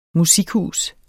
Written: musikhus

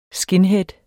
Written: skinhead

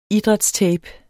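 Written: IDRÆTSTAPEN

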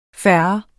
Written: få

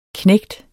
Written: knægt

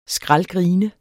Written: skraldgrine

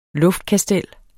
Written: luftkastel

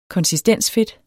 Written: konsistensfedt

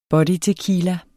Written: body

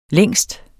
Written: længst

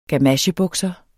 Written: gamachebukser

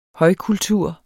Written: højkultur

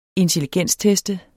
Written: intelligensteste

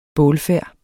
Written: bålfærd

